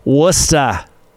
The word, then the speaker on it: Worcester: So it's pronounced Worcester. – Worcester. Worcester. – Worcester. No, ah. Ah, ah. – Worcester? – Like somebody smacking your tongue with a meat tenderizer. Worcester. – Worcester. – Ah, that hurts.